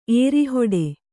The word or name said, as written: ಏರಿಹೊಡೆ